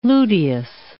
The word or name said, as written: luteous